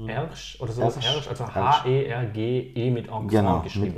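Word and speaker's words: Hergé: Und hat aus R-G das einfach französisch ausgesprochen und nannte sich Ersch.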